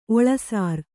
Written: ಒಳಸಾರ

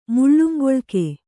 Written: ಮುಳ್ಳುಂಗೊಳ್ಕೆ

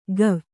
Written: ಗವ್

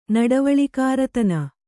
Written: ನಡವಳಿಕಾರತನ

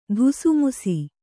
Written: ಧುಸುಮುಸಿ

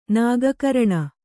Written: ನಾಗಕರಣ

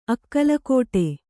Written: ಅಕ್ಕಲಕೋಟೆ

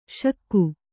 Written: ಷಕ್ಕು

